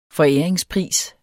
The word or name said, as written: foræringspris